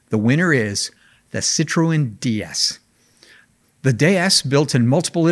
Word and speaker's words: DS: Now, the DS, a clever play on words that is pronounced the same as the French word for goddess, was the first mass production car to feature hydropneumatic suspension and disc brakes.